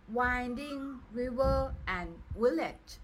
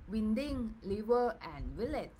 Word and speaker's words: Winding River and Village: Then the next thing I'm missing from the previous episode is the Professor Professor Song Kyun Kim books uh, name uh, actually is Winding River and Village. Okay, it's not winding liver and village okay and it's mispronunciation problem